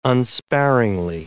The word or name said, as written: unsparingly